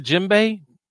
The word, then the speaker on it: djembe: You see the djembe? Pronounced with a silent D.